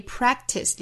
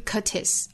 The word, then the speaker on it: practice: 注意 practice 里面的 k. 的发音是做单词内部的失去爆破的，不要读成 practice, 不是 curtis, 而是 practice, practice, 几乎听不到这个 k 的发音了，